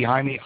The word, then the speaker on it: Jaime: It looks like Jamie, but it's not pronounced that way. Avalos, Anyway. – That would be Jaime Avalos.